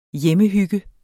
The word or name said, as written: hjemmehygge